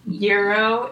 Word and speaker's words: gyro: We had kind of talked about this before, I know, but I always mispronounce it and always call it a gyro. – Oh, yeah. We literally Googled this, what, 4 hours ago? And I still already for... – Euro. It's like, it's like the money.